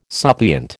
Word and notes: suppliant: American English

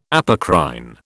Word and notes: apocrine: British English

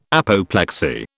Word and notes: apoplexy: British English

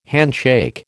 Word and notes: handshake: American English

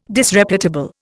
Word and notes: Disreputable: Indian

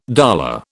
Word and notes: dolour: British English

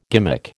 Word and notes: gimmick: American English

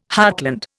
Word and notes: heartland: Indian

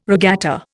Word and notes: regatta: Indian

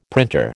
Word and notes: printer: American English

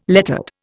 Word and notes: lettered: Indian

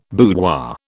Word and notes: boudoir: British English

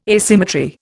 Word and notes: asymmetry: Indian

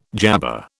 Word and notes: jabber: British English

